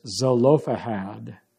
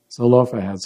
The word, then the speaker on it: Zelophehad: By the way, the name here is Zelophehad. Zelophehad.